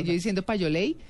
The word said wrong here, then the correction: payola: y es Payola, hola, ah, total.